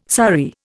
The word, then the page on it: sari: Indian